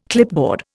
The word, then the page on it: clipboard: Indian